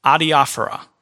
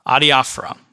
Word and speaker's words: adiaphora: Number one, you need to know the word adiaphora. Adiaphora.